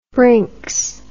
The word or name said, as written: brinks